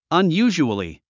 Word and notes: unusually: ʌnjúːʒuəli; アンユージュアリィ

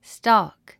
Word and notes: stalk: stɔ́ːk; ストーク

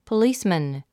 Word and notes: policeman: pəlíːsmən; ポリスマン

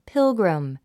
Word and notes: pilgrim: pílɡrim; ピルグリム

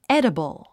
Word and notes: edible: édəbl; エデボォ

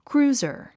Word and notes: cruiser: krúːzər; クルーザァ

